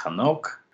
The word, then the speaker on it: Enoch: And it comes out that way in Latin, even though the Hebrew is actually Enoch, with that guttural clearing the throat kind of sound at the beginning, which of course is not a feature of the Greek from which the Latin translation was derived.